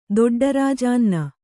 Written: ದೊಡ್ಡರಾಜಾನ್ನ

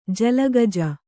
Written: ಜಲಗಜ

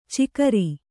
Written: ಚಿಕರಿ

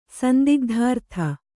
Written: ಸಂದಿಗ್ಧಾರ್ಥ

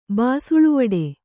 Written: ಬಾಸುಳುವಡೆ

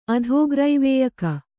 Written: ಅಧೋಗ್ರೈವೇಯಕ

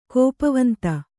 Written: ಕೋಪವಂತ

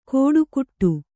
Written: ಕೋಡುಕುಟ್ಟು

